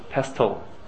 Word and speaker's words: pestle: Is the T sound to the pestle? It's pestle. You don't think so? – No, yeah. – Pestle. – Maybe it's like often, which definitely a T should not be pronounced, but the fact you'll say it.